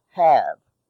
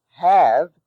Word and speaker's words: have: R, A-R-E, rhymes with car. Have, H-A-V-E, although it is in the cave-save spelling family, is pronounced have. There is no word in English pronounced have. However, as a syllable, H-A-V-E is pronounced have in the word behave.